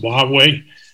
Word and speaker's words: Huawei: It sounds pronounced, Huawei.